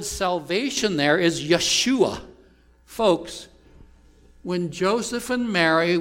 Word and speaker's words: Yeshua: Yeshua. That's the Hebrew pronunciation.